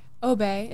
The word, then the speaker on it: Obey: Ariel, how do you say your last name? – Obey. It's French.